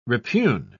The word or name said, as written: repugn